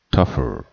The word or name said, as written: tougher